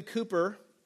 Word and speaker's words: Cowper: William Cowper is a famous English poet from the 1700s. His last name is spelled like Cowper, C-O-W-P-E-R, but it's pronounced Cowper.